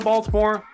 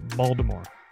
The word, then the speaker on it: Baltimore: Yeah, got home from Baltimore last night. It's actually pronounced Baltimore. Don't say Baltimore. They'll correct you. It's Baltimore. – It's like saying like, New Orleans. – Almost think like bald head, like Baltimore.